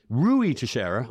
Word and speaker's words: Teixeira: Rui Teixeira, I'm told is how you pronounce his name,